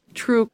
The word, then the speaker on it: TRU: Federal officials think that 3 million gallons could be classified as transuranic waste, or TRU for short. Pronounced? – True.